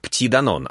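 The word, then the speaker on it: Petit Danone: Детский йогурт «Растишка» от Данон на западных рынках продается под маркой «Пти Данон», что читается как «Пти Данон», а переводится как «Данончик».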